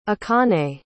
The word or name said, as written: akane